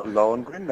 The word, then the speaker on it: Lohengrin: No. Lohengrin, that's a better attempt than a lot of people.